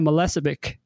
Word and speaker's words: Milicevic: I'm very excited to have today's guest, Anna Milicevic.